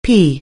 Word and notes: pea: anglais

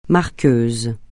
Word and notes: marqueuse: francais

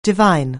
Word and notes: divine: anglais

